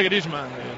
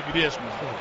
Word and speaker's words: Griezmann: Griezmann intenta tocar para Coque, pero no se dice Griezmann. – Es verdad. – Vamos a ver, en francés se dice Griezmann, no Griezmann.